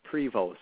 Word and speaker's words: Prevost: So it's Prevo. So Cody, C-O-D-I-E. And then Prevo, P-R-E-V-O-S-T, just like the tour bus.